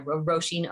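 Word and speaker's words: Róisín: And all my handles on Facebook, Instagram, TikTok, all those are Roisin Ó Music, or O-I-S-I-N-Ó Music. – I'm glad you spelled it, because it isn't spelled like it sounds, you know, for the benefit of those around the world.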